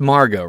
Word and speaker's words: Margot: It's Margot, right? It's pronounced Margot, the T silent.